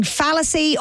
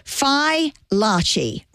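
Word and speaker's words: Fai Laci: So it's spelled F-A-I and then next word, L-A-C-I. So it's Fi La Chi. They're from Boston. Fi, F-Y-E, L-A-H-C-H-E-E. Because I've been saying fallacy or filacy, but no. So Larchi, Fi Larchi, rhymes with botchy, Larchi. So Fi Larchi.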